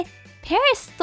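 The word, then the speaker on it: Paris: るかと思いますが偉大なや大きなを指す great を使います次はヨーロッパフランスに行きましょう首都パリスに着きました日本語ではパリですが、英語だと最後に S がついて、パリスと発音します。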